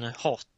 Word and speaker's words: Hoth: Hur säger man egentligen? – Jag alltid sagt, men Hot säger de i filmerna väl? – Ja, kanske de gör. – Det borde uttalas Hoth. – Men ja, de säger ju inte, de säger hot. – Gör det enkelt så säger vi hot.